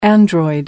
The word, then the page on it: android: American English